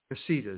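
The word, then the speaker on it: Necedah: Now, the same thing happened in Necada, Wisconsin, and Necada, Wisconsin was condemned. – Nisida is the way you pronounce it.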